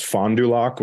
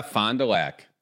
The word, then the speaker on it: Fond du Lac: People make fun of me, but it's Fond du Lac, Wisconsin. – Oh, yeah. Fond du Lac. – Fond du Lac.